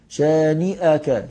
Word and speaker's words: شانئك: إن شانئك، في بعض الناس بينطقوا الكلمة دي غلط، يقولوا إن شانئك، طب ما فيش مد، أنت ليه بتمد؟ تقول إن شانئك، من النون للشين لا تفصل بينهما بمد، لا تقول إن شانئك، إن شانئك هو شانئك، طبعا دي فيها خطأ كتير من بعض الناس، يقول يعني قد لا ينطقها نطقا صحيحا، ولكن الصحيح فيها شانئك،